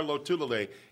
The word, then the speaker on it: Lotulelei: Happy to have Bill's new defensive lineman star, Lotulelei, in studio with a star.